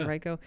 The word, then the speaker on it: Henrico: So we finally got it all set up that we were going to meet her in Henrico, which is kind of in between. Did I say that right? – Yeah. – Henrico.